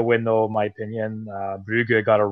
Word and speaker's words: Brugge: was playing Brugge. – No, you said it right, Mike. I butchered it.